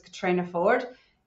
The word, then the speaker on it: Caitriona Forde: So for all of those that don't know me, my name is Caitriona Forde. Yes, it's spelled a little bit differently. It is the Irish spelling, and I get asked that all the time. I'm originally from Ireland, but I've been living in Australia, Western Australia, in Perth for coming up on nearly 12 years now.